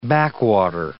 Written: backwater

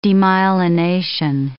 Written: demyelination

